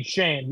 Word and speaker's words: Schoen: I think basically the problem is that the GM, Joel Schoen. I think that's how you say his name, right? Schoen. Shane. Yeah, I can never pronounce those SH names.